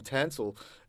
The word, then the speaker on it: Tansel: Like my name, Tansel. People either forget it or they really remember it. They go Tinsel, Tonsel. tonsil or yell out, hey, you Tansel, you know, so there's a lot made up.